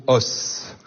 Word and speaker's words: os: Parce qu'on dit un os des os. Oui. Donc ça s'écrit bien de la même façon, mais ça ne se prononce pas tout à fait de la même façon entre le singulier et le pluriel.